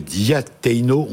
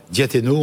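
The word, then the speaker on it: Diateino: Alors, ce livre, DRH, Mission ou Démission, c'est chez Diateno, on prononce comme ça Exactement. – Diateno, on peut le trouver.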